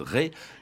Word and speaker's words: Retz: Est-ce qu'on dit Ré ou Retz? Parce que alors, ça s'écrit R-E-T-Z. Hein. – Oui, ça s'écrit R-E-T-Z, comme le pays de Ré au sud de Nantes, d'où d'ailleurs il tire son nom, mais là-bas on prononce Ré. Alors lui-même, à la fin de sa vie, écrivait R-A-I-S, donc je pense qu'il faut se plier à la coutume.